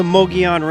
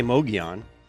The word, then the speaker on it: Mogollon: The Mogollon Rim, they say, is probably one of the most mispronounced locations in Arizona. I say Mogollon. Locals pronounce it more Mogollon.